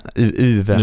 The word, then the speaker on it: Uwe: Jag vet inte ens hur man uttalar det. – Ja, han heter Uwe.